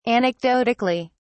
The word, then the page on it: anecdotically: British English